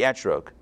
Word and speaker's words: etrog: I'm telling you what it means, and make sure to tell everyone else it means the esrog, or the etrog. I'm just going to pronounce it like the Ashkenazi way.